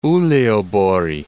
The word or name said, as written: Uleaborg